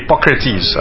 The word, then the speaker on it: Hippocrates: Now, 2,400 years ago, there was a guy called, I don't even know how to pronounce this, Hippocrates? Okay, Hippocrates.